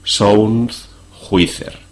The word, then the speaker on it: Sound Juicer: Deletreamos este segundo término J-U-I-C-E-R. Repetimos el nombre completo del programa castellanizando la pronunciación. Sound Juicer.